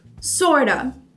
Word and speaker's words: sorta: Juntos, ele tem esse som do sort of ou do sort of, que é como se fosse um A no final. Então, quer praticar a pronúncia? Sort of ou sorta.